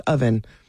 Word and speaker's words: Owen: So we're like, oh, it's not Owen, it's Oven. Even though it looks like Owen, the two V's together, much like Howdy.